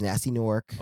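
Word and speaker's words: Gnasty Gnorc: Did you pronounce the G in the first one? Nasty? – No. Nasty Nork. – Oh, okay, yeah. I thought you still had the G in there, like Nasty. – Nasty.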